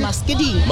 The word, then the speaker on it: muscadine: How do you pronounce it? Muscadine? – Muscadine. – Muscadine bloodline coming up at 9 o'clock.